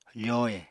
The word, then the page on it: fohe: apatani